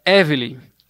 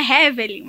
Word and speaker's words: Hevelly: É por causa do H, ele se escreve H-E-V-E-L-L-Y. Aí é, muitas pessoas pensam que é Hevelin, mas não é Hevelin, é Evely o H é mudo.